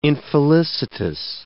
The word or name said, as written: infelicitous